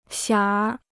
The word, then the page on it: 馅儿: xiàn r